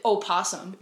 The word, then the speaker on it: opossum: Because you don't pronounce it opossum. – There's two different animals. – I'm talking about the possum that starts with an O. – Opossum. You pronounce the O.